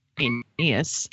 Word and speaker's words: Aeneas: Then we have Aeneas, which you really need to pronounce carefully, Aeneas, who is a commander and a leader of Troy.